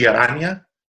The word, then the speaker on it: Aranya: My last name is actually Aranya, but I never force anyone to say it like that because it's a little bit of a mouthful.